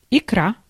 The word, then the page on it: paste: /peɪst/